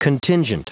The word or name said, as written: contingent